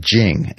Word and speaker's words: Jing: So they had concepts like Jing, and that's spelled J-I-N-G. We transliterate it as Jing.